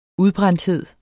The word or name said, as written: udbrændthed